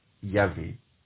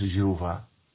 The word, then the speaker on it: Yahvé: Et c'est Yahweh ou Yahvé. Yod, He, Wav, Ké. Et qu'on traduit souvent par, qu'on prononce souvent Yahvé. D'autres prononcent Jéhovah.